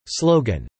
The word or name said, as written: slogan